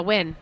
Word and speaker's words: Nguyen: I thought an interesting new pick was Christina Nijin from Hi Hi. – Yeah, Nguyen. Christina Nguyen. That's how you say her name. – You do? – Yeah. – Nguyen is Nguyen? – Yes. – Amazing. Yes. Thank you for telling me that. Gosh. It's going around saying Nijin for a long time.